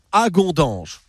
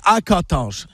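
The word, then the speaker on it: Hagondange: Au fil de la Moselle s'arrête cette semaine à Agondange. Je le dis bien. Non, je le dis mal, on dit Agondange avec un K et CHE à la fin.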